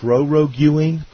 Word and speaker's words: proroguing: Now, what exactly is proroguing parliament? – Proroguing. – Yeah. – It's pronounced. It's kind of pronounced like pierogi.